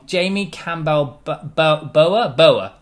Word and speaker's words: Jamie Campbell Bower: Actually, I said it wrong. Jamie Campbell Bower. That's it.